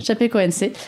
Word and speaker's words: Chapecoense: Chapecoense. – Chapecoense.